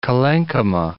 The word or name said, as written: collenchyma